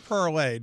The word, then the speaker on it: Parler: So is it Parler or Parlay? Do we know that? – It's French, and the verb is P-A-R-L-E-R, parlor, but that's pronounced parlay, so many people will spell it P-A-R-L-E-Y.